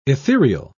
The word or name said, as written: ethereal